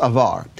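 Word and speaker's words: avar (אבר): And the first one is spelled Aleph Beit Resh, and it's pronounced avar.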